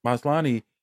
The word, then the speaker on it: Maslany: Maslany doesn't have really long hair in the trailer.